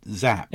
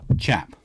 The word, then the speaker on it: Czap: Is it Kevin Zapp? – Yeah, it's Chap. – Chap, right, okay. – I think Chap is how it's pronounced. – That's, I get you. – At least that's how he, like, it's on their website. It's just like right, Kevin CZAP.